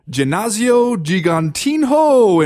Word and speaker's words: Ginásio Gigantinho: I am, and I don't want to sound like a braggart here, but I'm 100% positive that I'm pronouncing this correctly. Ginásio Gigantinho in Porto Alegre, Brazil. – Let's see, nailed it.